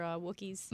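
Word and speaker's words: Wookiee: Or the other Wookiees. – Mm-hmm. Wanted to get back Wookie. – To Kashyyyk. – Yeah. Did you notice they pronounced it Wookie? – Yeah, they pronounced it like the proper way.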